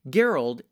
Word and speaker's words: Gerald: Gerald, yes, it's a hard G, folks, thank you.